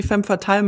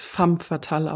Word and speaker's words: Femme Fatale: dass ich gerne die Femme Fatale mache, aber man spricht's Femme Fatale aus. Man schreibt es zwar Femme, aber es wird Femme Fatale ausgesprochen.